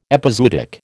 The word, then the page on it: epizootic: American English